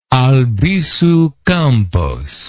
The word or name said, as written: albizu campos